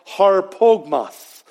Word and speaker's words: harpagmos: The meaning of grasp in Greek is pronounced harpagmos. Harpagmos.